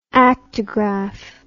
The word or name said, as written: aktograph